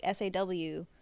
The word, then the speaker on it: Saw: I thought it was Saul for a long time because the way they his name's Saw, like S A W, but they pronounce it like Saw.